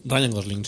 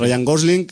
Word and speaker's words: Ryan Gosling: Y aparte de eso, pues tenemos al señor Ryan Gosling, se dice así, ¿no? Creo. – Ryan Gosling. – Sí. Ryan Gosling